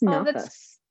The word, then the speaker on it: nafas: What is nafas? Did I say it right? – Yeah, you did.